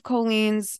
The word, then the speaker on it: Colleen: So one of Colleen's, it's, by the way, I'm not pronouncing that wrong. It's Colleen.